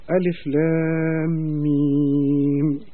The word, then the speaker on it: الم: بدأته بثلاثة حروف مفردة، هي الألف واللام والميم، وتنطق ألف لام ميم هكذا: ألم ترى وأنت تقرأها وأنت تسمعها، ألم تقف مع نفسك وتسأل هذا السؤال: ما معنى هذه الحروف؟